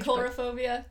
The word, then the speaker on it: coulrophobia: Have we said coulrophobia? – Coulrophobia, is that how you say it? – Fine. – It's C-O-U-L. – Cauliflower phobia. – Cauliflower phobia. C-O-U-L-R-O-P-H-O-B-I-A.